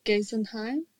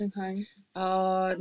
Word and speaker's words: Geisenheim: Is it Geisenheim? – Yes. – Did I say it right? – Yes. – Geisenheim.